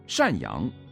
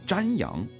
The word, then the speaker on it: 赡养: 而当他把精湛念作精慎，赡养念作瞻仰，且在发音中被镜头拍到岿然不动这个词竟然有注音时，人们就看出他识字不多，而且根本不知道自己念的是什么。